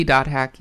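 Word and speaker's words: .hack: Like, there's one map that takes place in the Dot Hack world. It is Dot Hack, right? That's how it's pronounced? That's how the kids say it these days? – It should be Dot Hack, yeah.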